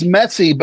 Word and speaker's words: Messi: Well, his name is Messi, but that's how you pronounce it in Argentina.